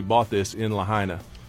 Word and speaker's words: Lahaina: Lahaina. – Lahaina.